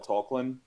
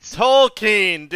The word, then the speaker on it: Tolkien: Tolkien, but the ironic thing is... – He can't even say that right! – Tolkien, dude!